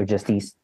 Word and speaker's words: Justice: You know, there's a musical act, Justice or Justice, as the French recently taught me it was actually pronounced.